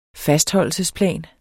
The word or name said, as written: fastholdelsesplan